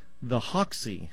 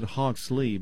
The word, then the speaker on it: Hoxie: Okay, and the other one would be called the Hoxie formula. – Okay. – I right. always pronounce it Hoxley, but it's not Hoxley. It's Hoxie.